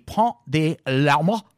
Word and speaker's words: Pont de l'Alma: the Pont de l'Alma tunnel. That's exactly how you pronounce it.